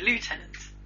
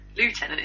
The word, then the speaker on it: lieutenant: Lieutenant is how it's written, but we pronounce it lieutenant. But the Americans can't be arsed with that shit. They say lieutenant. – Oh, I that's say how lieutenant. It's spelled. Well, that's the American way. If you're proper English, you should say lieutenant.